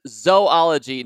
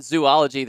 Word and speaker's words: zoology: One of my pet peeves is apparently it is pronounced zoology, not zoology. There are not three O's after the Z in zoology.